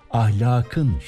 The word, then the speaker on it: ahlakın: Ahlak da Arapça. O nedenle ek geldiğinde K yumuşamıyor. Ahlakın değil, ahlakın şeklinde yazılıp okunuyor.